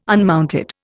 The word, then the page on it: unmounted: Indian